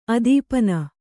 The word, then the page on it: ಅದೀಪನ: kannada